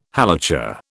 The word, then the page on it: Halacha: British English